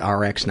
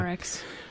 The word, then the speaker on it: RX: I assume you pronounce it RX, not prescription. – Yeah, that's right. Yeah. RX.